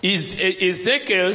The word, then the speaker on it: Ezekiel: Ezekiel.